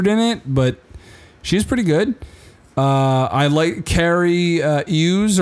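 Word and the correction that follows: Cary Elwes: or whatever. Ewes. – Carrie Elwes. How do you not know who that is? – No, he's awesome. Okay. I just don't know how to pronounce his name.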